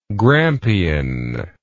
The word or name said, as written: Grampian